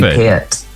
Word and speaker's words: Peart: Lee Peart, to rhyme with ear. Yes? – Oh, yes. Well, some people say Pert. – Do people get it wrong often? – Peart. Oh, all my life. They say Pert.